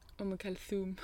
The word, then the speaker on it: Umm Kulthum: Is it Umm Kulthum or Umm Kulthum? – Umm Kulthum. It's with a...